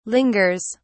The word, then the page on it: lingers: British English